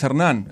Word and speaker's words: Hernan: My name is Hernan. You can find me in my social media. Can I say that? – Yeah. – In Hernan Rostan. Hernan with the H. So I will spell it. H-E-R-N-A-N. And Rostan is R-O-S-T-A-N.